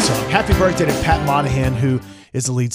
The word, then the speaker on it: Monahan: Pat Monahan is 50. I know I said it wrong. – Monahan. Okay.